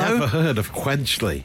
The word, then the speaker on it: Quedgeley: I don't know if I'm pronouncing this right. It's Quedgeley Wanderers Football First Team. They're a team based in Gloucester. – Quedgeley.